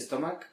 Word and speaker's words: stomach: Don't pronounce the CH, the stomach, como CH.